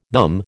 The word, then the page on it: them: American English